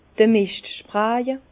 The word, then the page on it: d'r Mischt spraje: Bas Rhin; Herrlisheim